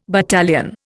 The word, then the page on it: battalion: Indian